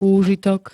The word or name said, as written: úžitok